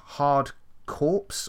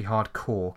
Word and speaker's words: Corps: That might be Hardcore, because it's C-O-R-P-S, but that means core, doesn't it? Or that's pronounced core. And I think Contra is a game about, like, army. And within the realms of the army, C-O-R-P-S is pronounced core.